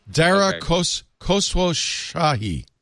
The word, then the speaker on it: Dara Khosrowshahi: Dara Khosrowshahi.